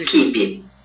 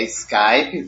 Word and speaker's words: Skype: Skip. – Ele falou Skip, mas a pronúncia é Skype, porque é em inglês.